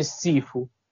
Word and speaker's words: Shifu: Che poi noi te- eh, questa devo dire una, uno stato uno sbaglio di traduzione e Shifu si, si scrive S-H-I-Fu ma si legge Sifu S-I-F-U.